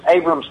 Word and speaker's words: Abrams: Landing, their Abraham, M1 Abraham, M1... I'm not even saying it right. – Abrams tanks. – Right. M1 Abrams tanks.